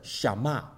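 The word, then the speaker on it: Shama (שמע): 西面这个前面那个字的发音呢，就像是 Shama。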